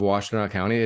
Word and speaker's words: Washtenaw County: So I think a better introduction would be Hillary, the Apple Princess of Washtenaw County.